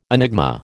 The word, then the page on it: enigma: American English